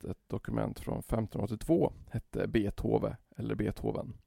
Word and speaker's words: Beethoven: Problemet är att dessa namn uttalas med ett kort e i början och att Beethoven har ett långt.